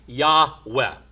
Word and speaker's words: Yahweh: Probably, those who study these things say that probably a better pronunciation of the Hebrew word would be Yahweh. Y-A-H-W-E-H.